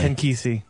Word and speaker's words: Ken Kesey: If he says no, I'll say Ken Casey was better than you anyway. I'll rub that on him. Well, why don't you pronounce the name properly? – Ken Kesey.